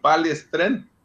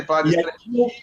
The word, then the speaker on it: palestrante: E pronunciado como nós pronunciamos no Sul, palestrante. Nada de palestrante.